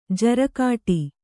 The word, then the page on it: ಜಾರುಗಾಡಿ: Kannada